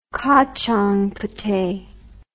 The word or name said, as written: kachang puteh